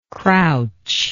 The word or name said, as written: crouchingly